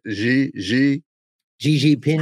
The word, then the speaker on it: Xi Jinping: And that's a very recent photo of Putin and, how do you say it, Xi Jinping.